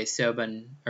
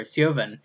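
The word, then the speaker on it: Siobhan: And I was actually just looking up, and I still don't know how to pronounce her name, but we're going to say Sioban or Sioban. It's probably the BH is a V, just like Bonahaven.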